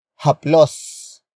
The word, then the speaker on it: haplos: Haplusin comes from the root word haplos. Haplos. Haplos. Which is spelled as H-A-P-L-O-S.